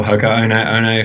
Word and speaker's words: Hoka One One: We know that hardcore runners are generally aware of Hoka 1-1. Or Hoka One One, if I use the correct Polynesian pronunciation, which I usually don't.